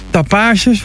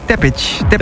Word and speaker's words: Tapage: Dan Tapages, voor mij dan moet ik het zo uitspreken. Heeft dingen uitgebracht op bij Cardiac. – Tapage. Geen Tappage.